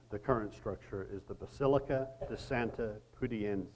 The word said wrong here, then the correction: Pudenziana: if I said that right. My wife's going to correct me. Say it again. Pudenziana.